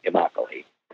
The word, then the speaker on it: Immokalee: And it was almost a full year before I realized that I'm a collie was Immokalee. – Yeah, the best thing anyone ever said to me, and I forget who it was, but they said rhymes with broccoli.